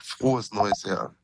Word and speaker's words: Frohes Neues Jahr: Dennis, we wanted to start by saying Froy Noy Yar. Is how was that? – Was not bad. Frohes Neues Jahr. – Okay. Yeah. – That's how you say it.